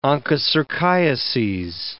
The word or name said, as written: onchocerciases